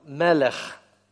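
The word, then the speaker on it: мелех (מלך): Вот первое слово «мелах», второе «мелех». Слышите разницу? «Мелах», «мелех».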